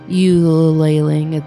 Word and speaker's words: ululating: You can tell if one is near when they make a ululating. I said that wrong, didn't I? – Ululating. They're ululating. You can tell if one is near when they make a ululating high pitched call.